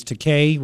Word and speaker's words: Takei: And he said it's Takei, rhymes with gay. I think is what he said.